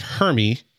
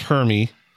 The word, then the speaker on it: Hermie: The misfit elf Hermie. That's how you say it, right, Hermie? – Sure.